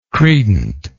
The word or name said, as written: credent